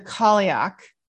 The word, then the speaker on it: Cailleach: Okay, so the Kaliuk, and if you see this word, you struggle because the way that it's spelled is C-A-I-L-L-E-A-C-H.